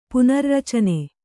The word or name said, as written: ಪುನರ್ರಚನೆ